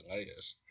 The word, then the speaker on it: Elias: And it's Elias.